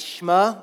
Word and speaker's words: Shema: The word is actually shema, which doesn't sound very like biblically fancy, but it's shema. S-H-E-M-A. It looks like shema. You pronounce it shema.